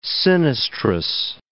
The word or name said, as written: sinistrous